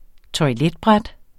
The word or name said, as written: toiletbræt